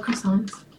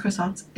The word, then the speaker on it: croissant: They sell croissants. Which also, my sister's gonna be mad, but it's actually not, it's not pronounced croissants, it's pronounced croissant, okay? – She's oh, my bad.